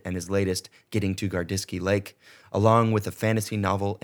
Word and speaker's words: Gardisky Lake: Paul, I wanted to talk to you today about your latest book, Getting to Gardiski Lake.